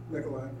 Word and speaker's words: Nicolai: But Pastor Nicolai, is it Nicoli or you're Italian, aren't you? And I see you, well, how would you pronounce that? Nicolai, okay.